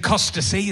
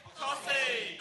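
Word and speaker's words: Costessey: That was in Costacy. Is that how you say Costacy? Whoa, fuck no. How do you say it? – Cossy. – Cossy, okay. What? There's a fucking T and E, Dad.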